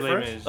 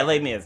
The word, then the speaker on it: Les Mis: I'm talking about Les Mis Mirage. I can't pronounce it. – Les Mis. – Oh yeah, Les Mis.